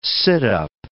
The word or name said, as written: sit-up